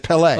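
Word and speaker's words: Pelé: It's Pele. – On the second syllable.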